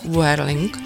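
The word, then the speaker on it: Wuerling (五二零): Ano, takže například 520 znamená miluji tě, protože se to vyslovuje jako Wuerling a to zní podobně jako Wuaini, čili miluju tě.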